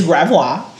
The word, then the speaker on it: Gravois: It's Gravois. Because this is a French ass sound.